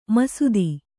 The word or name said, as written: ಮಸೆತ